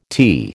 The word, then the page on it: tea: American English